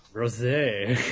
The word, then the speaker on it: Rosé: Rosé. Well, it depends what the accent on the E is.